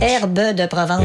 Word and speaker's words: Herbes de Provence: Herbes de Provence. – On prononce pas le S.